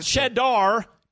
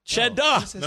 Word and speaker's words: Cheddah: Not Cheddar, Cheddah. – No, we're not gonna. No, Cheddar is not. Get that Cheddar. We're not doing that. – No, we're saying Cheddah. Cheddah.